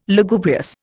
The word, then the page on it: Lugubrious: Indian